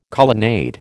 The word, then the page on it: colonnade: American English